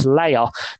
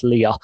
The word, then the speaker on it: Leia: It actually was Princess Leia, but we are going to unfortunately take the 2 points away from you due to the pronunciation. So I do apologize, but unfortunately we have to do that. It's only fair on the competition. So it was actually indeed Princess Leia, not Princess Leia.